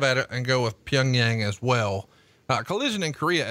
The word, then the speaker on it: Pyongyang: But I think the consensus is, at least here in the United States, it's pronounced Pyongyang. Okay. I've heard it Pyongyang, Pyongyang. I've heard it different inflections, but I say Pyongyang.